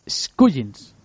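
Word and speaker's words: Skujiņš: ¿cómo lo has pronunciado? ¿Cómo se dice? Scullins. Scullins, vale.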